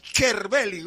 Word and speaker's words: Cervelli: Nosotros le decimos Cervelli, pero ellos cuando lo pronuncian dicen Cervelli. O sea, es que la C en italiano se pronuncia como Che.